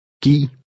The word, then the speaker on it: Giv: The V is silent. – Giv mig det. Giv.